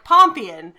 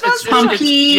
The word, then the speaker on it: Pompeian: Or how do you solve a problem like Pompeian? And then he wrote the email. – No, it's Pompeian. – Yes, there we go. You stressed the wrong syllable.